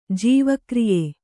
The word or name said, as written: ಜೈವಕ್ರಿಯೆ